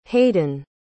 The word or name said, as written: haden